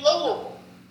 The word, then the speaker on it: Louisville: They say Louisville. Okay. Bille is like bull. All right. Same as Knoxville. It's not Knoxville. It's Knoxville. Every Bille, a Southerner should be saying it like it's bull. V-U-L.